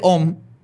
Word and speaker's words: Aum: El sistema de creencias de Om cambiaría, que se, escribe a u Om. Um. – Um. Um. – se pronuncia om, pero es AUM.